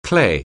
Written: clay

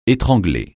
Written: étrangler

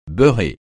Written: beurré

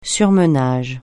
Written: surmenage

